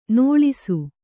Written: ನೋಳಿಸು

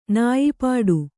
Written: ನೊಯ್ಪಡು

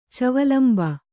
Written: ಚವಲಂಬ

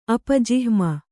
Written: ಅಪಜಿಹ್ಮ

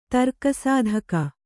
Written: ತರ್ಕಸಾಧಕ

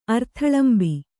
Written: ಅರ್ಥಲಂಬಿ

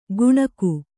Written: ಗುಣಗು

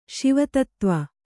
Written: ಶಿವತತ್ತ್ವ

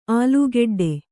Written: ಆಲುಗಡ್ಡೆ

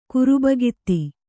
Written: ಕುರುಬಗಿತ್ತಿ

